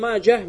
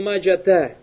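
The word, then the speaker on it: Маджах (ماجه): Что касается Ибну Маджах, Маджах это имя человека. То есть и обязательно надо произносить эту ха. Хоть ты останавливаешься или же соединяешь другим словом. Фаля юкалю раваху ибн маджата. Это неправильно, то есть там арбуты там нет.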